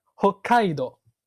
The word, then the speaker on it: 北海道: 但是日语又发生了语音的演变，现在的读音就变成了 hokkaido，o 变成了 o，然后这个 boku 和后面的科连了起来 hokkaido。